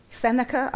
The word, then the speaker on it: Seneca: And there was someone who said, I think it was a philosopher called Seneca, I have no idea how you pronounce it. Is that right? – Yeah. – Great, I've got it right.